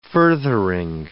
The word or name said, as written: furthering